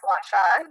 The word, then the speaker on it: gua sha: Yes, it's gua sha. You're saying it correctly.